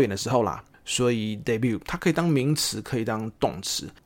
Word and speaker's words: debut: 那这个东西是 debut in 2022，debut 是 D-E-B-U-T，它不是 debut，好，不是 debut，debut，而且那个 T 一般是不发音，那这边会发一个轻轻的的那个声音，是因为它加了 ed 啊。